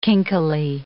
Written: kinkily

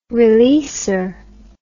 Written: releasor

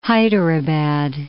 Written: Hyderabad

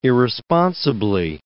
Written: irresponsibly

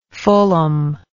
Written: fullom

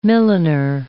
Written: milliner

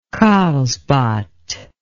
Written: karlsbad

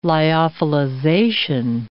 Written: lyophilization

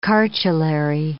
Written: cartulary

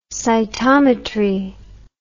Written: cytometry

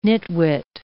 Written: nitwit

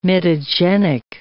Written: mitogenic

